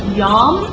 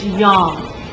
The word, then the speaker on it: yom: And the word day is pronounced yom. Y-O-W-M is the Hebrew spelling of day. It's yom. It's from sunrise to sunset.